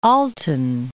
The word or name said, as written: Alton